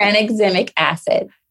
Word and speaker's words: tranexamic acid: How do you say it? – Tranexamic acid. – Yes.